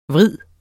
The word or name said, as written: vrid